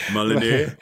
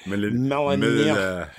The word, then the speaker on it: Molyneux: Molyneux. – Molyneux.